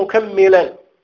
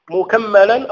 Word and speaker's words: مكملا: ثم قال مكملا, ويجوز أن تقول مكملا مكملا أو مكملا, كلا اللفظين صحيح.